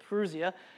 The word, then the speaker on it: parousia: I think I pronounced it correctly. That's how Google told me to pronounce it, parousia.